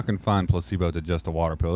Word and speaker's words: Placebo: It's pronounced Placebo. – Yeah, it's kind of like the pill. Kind of like the water pills.